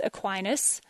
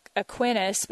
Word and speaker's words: Aquinas: And I won't mispronounce that like I got the month wrong at the beginning of the show. I tempted sometimes to say Aquinas because in Texas, that's how we would say it.